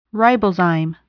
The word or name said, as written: ribozyme